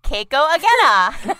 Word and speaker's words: Keiko Agena: Ginny meant Keiko Agena.